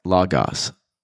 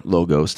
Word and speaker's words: logos: So the Stoics hold to the divine logos or logos, depending on how you desire to pronounce it. People pronounce it each way; it doesn't really matter. But he believed in the divine uh,